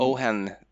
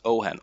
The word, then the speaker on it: Ojen: Men jag vet inte, känner du till, eh, känner du till en sprit som heter Ohen? Nej. Stavas Ojen. O-J-E-N. Mm. Ohen, det ska tydligen uttalas Ohen, återkommer gång efter annan i dessa böcker också.